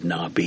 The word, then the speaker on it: Nabi: I believe it's actually spelled N-A-B-I. It would be pronounced Nabi, according to this, N-A-W-B-E-E, and it means a prophet or an inspired man.